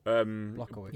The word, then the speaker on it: Blachowicz: However you say his name. Has got to do to Adesanya. – How do you say his name? – Which Blachowicz. – Jans. – Blachowicz. – We've got it.